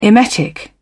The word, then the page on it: emetic: British English